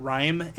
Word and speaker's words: Rime: By the way, do you know what Rime is? Mm-mm. It's like lime spelled with an R.